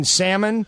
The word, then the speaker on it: salmon: If you go to the restaurant and the waitress pronounces the L in salmon, it's not going to be a good meal.